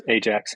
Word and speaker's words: Ajax: Is it called Ajax? Am I saying that right? – It is Ajax, yep.